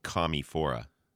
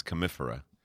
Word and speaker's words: Commiphora: So I mispronounced it. I was calling it commiphora, but it's commiphora.